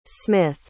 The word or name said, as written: smyth